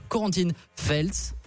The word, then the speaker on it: Corentine Feltz: Fabien, Maxime, Anna et moi-même, on a le plaisir d'accueillir pour cette dernière émission Corentine Feltz. – Bravo, très bien dit. – Ouais. – On applaudit. – Merci beaucoup. – On applaudit la prononciation.